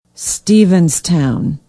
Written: stevenstown

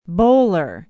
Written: bowler

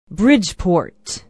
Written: bridgeport

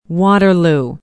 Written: waterloo

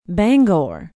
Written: bangor

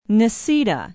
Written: necedah

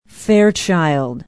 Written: fairchild

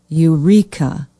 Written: Eureka

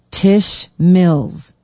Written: tisch mills

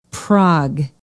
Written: praag

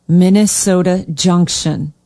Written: Minnesota Junction

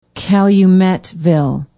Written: calumetville

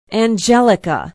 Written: angelica

